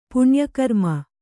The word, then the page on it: ಪುಣ್ಯಕರ್ಮ: puṇya karma